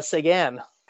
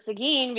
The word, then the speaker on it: Seguin: Tyler Seguin's name is pronounced incorrectly in Canada still, which is always funny to me. – How is it pronounced in Canada? – Seguin. – Well, down here in Texas, they pronounce it Seguin because we have the city that's spelled the same way.